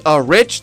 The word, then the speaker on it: Rich: So my name is Rich. That's spelled R-I-C-H.